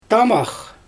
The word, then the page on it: taamax: Xyzyl